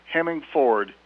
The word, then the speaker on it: Hemingford: Because as a nice lady once told me at a tavern in that town, she said, son, you pronounce it Hemingford, you don't drive a Ford.